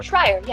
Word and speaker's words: Schreyer: Schreyer, yes.